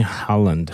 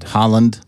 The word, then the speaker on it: Haaland: I don't know how you pronounce his name. – I think Haaland. It's Erling Haaland. – Haaland.